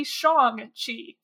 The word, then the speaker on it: Shang-Chi: So it should be Shang-Chi, from what I can tell.